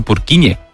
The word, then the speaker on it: Purkinje: En español sería Purkinje. – ¿Purkinje? – Pero Purkinje es en checo, que es por porque es el apellido del descubridor.